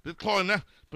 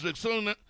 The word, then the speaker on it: Rexona: A friend called it the Corner, but guys, it's Rexona, like Rexona.